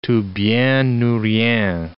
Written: tout bien ou rien

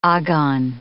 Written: agon

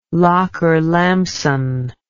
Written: lockerlampson

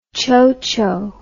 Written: chocho

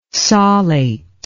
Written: solate

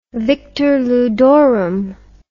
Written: victor ludorum

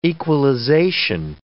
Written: equalization

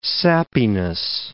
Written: sappiness

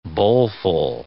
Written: bowlful

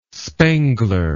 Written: spengler

